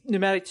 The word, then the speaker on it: pneumatic: I think pneumatic tubes are a very underutilized technology. – Yeah. They have a silent P.